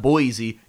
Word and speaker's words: Boise: It's not Boise, it's Boise.